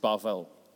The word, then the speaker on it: Babel: I looked it up, and apparently Babel is pronounced Bavel. And the word for confused is pronounced Balal.